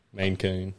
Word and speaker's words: Maine Coon: There's no way that's how you pronounce that name. – No, it is. – A Maine Coon. – It's two words. – Like a man coon. – Maine, like the state.